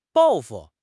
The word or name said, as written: 报复